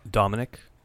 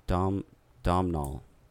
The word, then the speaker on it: Domhnall: Caleb is the main character, played by Domhnall Gleeson. I'm butchering these names. – Dominic? – No, Domhnall.